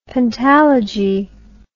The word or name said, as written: pentalogy